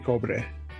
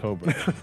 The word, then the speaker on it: Cobre: is Mariachi Cobre. Did I say it right? – Mariachi Cobre. – Cobre. – Yes, absolutely.